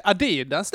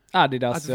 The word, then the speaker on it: Adidas: Adidas där är ju en sån grej som det finns betoningsskillnader på. – Adidas, att vissa säger, ja, exakt. – Ja, det lär ju vara Adidas egentligen, för han heter ju Adi Dassler.